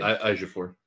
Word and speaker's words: Ejiofor: Chiwetel Ejiofor, which I probably butchered that name. – Ejiofor.